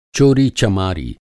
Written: चोरी-चमारी

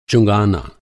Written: अंक

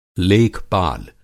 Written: लेख-पाल

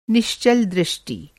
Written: निश्चल

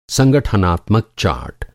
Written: संगठनात्मक

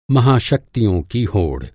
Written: होड़

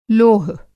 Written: लोह

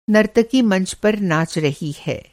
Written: नर्तकी

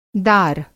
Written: दार

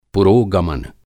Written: पुरोगमन

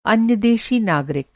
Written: अन्य-देशी

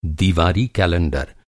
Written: कलेंडर

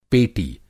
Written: पेटी

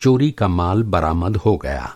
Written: बरामद

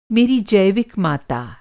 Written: जैविक

